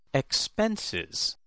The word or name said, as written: expenses